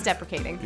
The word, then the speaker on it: deprecating: It's not self-appreciating, so it's self-depreciating? No. It's deprecating. – Yep. Deprecating. Make sure we put that. – That's what I said. – I know. – What did I say? – I'm just putting emphasis on the pro. – Okay. Did I say something? – Because it almost sounds like defecate.